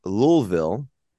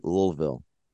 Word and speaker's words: Louisville: one is Louisville, which would be Louisville, like in Kentucky. My parents lived up in Owensboro, and they went up there, and like it was locals. They're like, it's like Louisville, like a Louisville, because it's like Louisville.